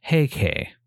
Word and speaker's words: Heike: Taira itself is a single kanji, so the phrase Taira clan is a compound of two kanjis pronounced as Heishi, and the phrase Taira house is thus pronounced Heike.